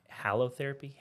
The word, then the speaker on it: halotherapy: And you pronounce it's ha- halotherapy, halo. – I say halo therapy. Halotherapy. A lot of people call it halotherapy.